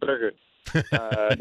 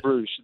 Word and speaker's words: Brugge: In Dutch, we say Club Brugge, not Bruges. That's the English pronunciation.